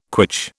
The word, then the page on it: quitch: British English